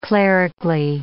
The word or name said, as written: clerically